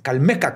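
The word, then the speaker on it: Calmécac: Fue también a esta edad que entró al Calmeacac. Calmecac. Calmecac. Es que tiene acento en la de Calmecac. Calmecac.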